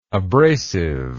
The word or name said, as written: abrasiveness